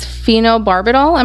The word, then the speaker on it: phenobarbital: So they went out in three groups, and their chosen poison was phenobarbital.